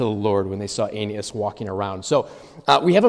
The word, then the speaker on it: Aeneas: I'm always careful when I pronounce that. I use three syllables instead of two. Met a man named Aeneas who had been paralyzed and bedridden for 8 years.